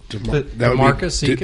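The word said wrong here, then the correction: De'Marquise: I believe it's DeMarquise.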